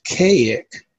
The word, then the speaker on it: Kazik: The name is pronounced Kaik, but it's spelled K-A-Z-I-K.